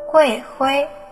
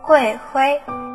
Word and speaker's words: 桂花: 今天要教大家的闽南语是“ 桂花”，读作“ 桂灰”。桂花读作“ 桂灰”。